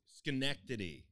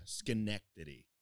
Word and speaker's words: Schenectady: Schenectady is how you pronounce it. – Oh, really? – Yeah. Schenectady.